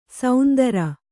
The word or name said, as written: ಸೌಂದರ